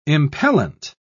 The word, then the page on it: impellent: British English